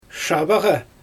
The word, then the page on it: ʃabaɣa: Xyzyl